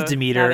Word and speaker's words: Demeter: I thought it was Demeter, but I also... – It is Demeter.